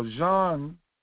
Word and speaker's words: Jean: DuSable, Jean, or Jean, as the French pronounce it, Jean-Baptiste Pont DuSable.